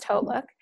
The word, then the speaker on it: HauteLook: A lot of people call it Hotlook. It's pronounced Hotlook.